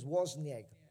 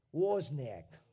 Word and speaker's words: Wozniak: It's about Steve Jobs, whatever, and his partner Steve, I can't really pronounce that name, but it's Wozniak. Wozniak.